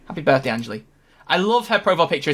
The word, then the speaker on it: Anjli: Doctor just tweeted out that it's uh, uh Ange, Ange, Angel. Is it how you say her name? Anjli. Anjli.